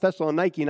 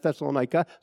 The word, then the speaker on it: Thessaloniki: And that's how a Greek person who came into my church one time said, that's how it's pronounced. That's the way they say it in Greece. Say it Thessaloniki, not Thessalonica.